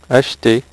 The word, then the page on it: acheter: French